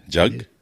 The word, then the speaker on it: Jagmeet: Okay, how is it pronounced? – Jagmeet. – Oh, okay. – Jug. – J, and he spells it out that way phonetically. Yeah. Well, J-U-G-M-E-E-T.